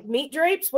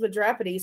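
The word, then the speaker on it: Mitropoulos: Meat Drapes? What kind of name is that? It's Madropity, sir. It's Greek. I think you're pronouncing it wrong. This says Meat Drapes. Actually, it's pronounced Madropity.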